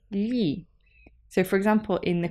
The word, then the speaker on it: gli: Gli italiani. – And this gli is spelt G-L-I. And the pronunciation is a little bit different to in English, an English sound.